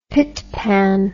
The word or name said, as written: pitpan